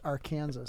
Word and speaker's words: Arkansas: It's pronounced Arkansas. – Arkansas, yes. You can't say it right.